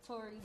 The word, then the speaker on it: Torie: My name is Torie Medusa. You can find me on Instagram, Twitter, all platforms. Torie Medusa. That's T-O-R-I-E Medusa. But on Snapchat, it's derogatory. Spelled like derogatory, but T-O-R-I-E.